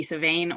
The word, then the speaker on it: Samhain: But it's spelled S-A-M-H-A-I-N, and the M-H makes a V or a W sound, depending on which part of Ireland you're from. So it could be Savane or Samhain.